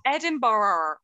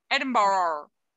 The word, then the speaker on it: Edinburgh: Just in case you wouldn't know, I love Edinburgh. I'm like, it hasn't got an O in it. It's just a U. There's no O. Edinburgh.